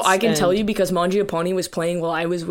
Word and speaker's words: Mangiapane: I pronounce it Mangiapane because that's like an Italian name and it means eat bread.